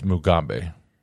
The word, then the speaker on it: Mugabe: And it rhymes with Zimbabwe? Say it again. – It's Mugabe.